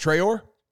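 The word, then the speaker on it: Traoré: It's pronounced Traore.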